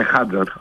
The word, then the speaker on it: Χάντζαρ: Καλά που τον είπες, γιατί δεν ήξερα πού τονίζεται. Δεν το ξέρω αυτόν οδηγό. Χάντζαρ είπες, Ναι, Χάντζαρ.